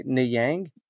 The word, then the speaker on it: Niang: Niang? – Close enough. He's Senegalese, so but it reads as French.